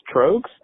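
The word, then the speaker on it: Tröegs: First of all, I just wanted to say, Denny, it is pronounced Trogues. Can you please learn how to say that? Drives me crazy being from the East Coast and, you know, hearing it pronounced correctly and having been to the brewery, and that's how they say it.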